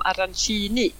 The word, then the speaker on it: arancini: de uttalas arancine i Palermo, medan i Catania så säger de arancini.